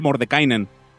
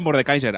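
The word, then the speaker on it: Mordenkainen: hablan de Mordekainen. Que no, que yo siempre lo digo mal. Es cuando lo dije en una partida, creo que estaba Aymar y Manoli, me dijeron el que juega el jugador del LOL. – Es que he vuelto a pensar otra vez lo mismo, en Mordekaiser. – Claro, yo siempre digo Mordekaiser. Ahora porque lo tengo escrito y lo estoy leyendo, pero Mordekainen es un mago súper tocho.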